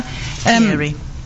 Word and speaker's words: Thierry: Is it Thierry Mugler? – Thierry.